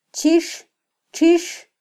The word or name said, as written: chixtxix